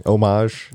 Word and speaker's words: homage: Homage. Mm-hmm. Homage. – So we were the soft G.